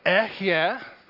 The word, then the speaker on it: Ehyeh: And in Hebrew it's pronounced Ehyeh, and it's an incredibly significant word.